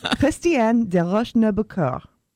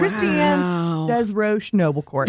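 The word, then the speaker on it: Christiane Desroches Noblecourt: Her name was, pardon my pretentious pronunciation, Christiane de Rochenoubecourt. That's how it's said in French. – Wow. – Christiane des Rochenoubecourt.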